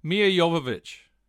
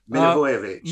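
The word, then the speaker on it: Milivojević: Mia Jovovich. – Milivojevic.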